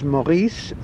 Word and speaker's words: Maurice: My name is spelt S for sugar, E-D-D-O-N. Because of my partly French origins, the Christian name is Maurice, M-A-U-R-I-C-E.